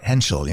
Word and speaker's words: Hentschel: Henshall, yeah.